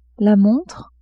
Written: montre

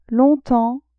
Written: longtemps

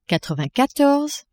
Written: quatre-vingt-quatorze

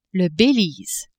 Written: Belize

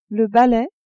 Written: balai

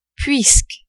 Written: puisque

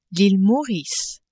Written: Ile Maurice

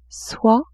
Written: soie

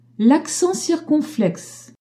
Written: circonflexe

